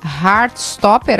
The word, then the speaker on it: Heartstopper: O Jax está lembrando também que tivemos a renovação para mais duas temporadas de Heartstopper, assim que se pronuncia, Tiziano, é isso? – Isso.